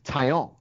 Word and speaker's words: Taillon: and it's Taillon, I believe is the proper pronunciation.